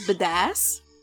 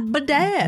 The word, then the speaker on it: badass: Did you just say badass? – Yeah. – Badass. – Badass. Yeah. – But badass. You put the wrong emphasis on the wrong syllable.